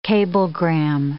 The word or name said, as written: cablegram